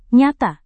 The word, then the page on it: nyata: Indonesian